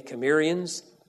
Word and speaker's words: Cimmerians: C-I-M-M-E-R-I-A-N-S. I'm from a Greek word, be pronounced with a hard K, carried over as an S with a hard K, Cimmerians, but it's spelled with a C.